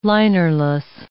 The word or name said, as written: linerless